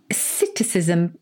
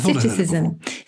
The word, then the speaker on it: psittacism: Citticism, but it's got a silent P. – Oh, the P is silent, as in swimming pool.